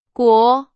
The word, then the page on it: 国: Chinese